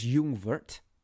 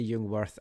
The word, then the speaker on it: Jungwirth: It's Jungwirth. So we have to try and get that going. I still want to say Jungwirth, and I probably still will say Jungwirth.